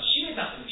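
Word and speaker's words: 七五三太: で、かかわらずこの新島さん、新島、本当はしめたという七五三と書いてしめたって読むそうなんですけれども、幼名はそういうしめた、新島しめた少年も大変学問に向上心の強い、また新しいことに対してチャレンジが強い、そういう子供である。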